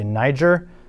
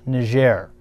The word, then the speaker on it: Niger: If I was just looking at it as an American, I would say Niger. But the internet, the Google, told me that it is a French nation pronounced Niger.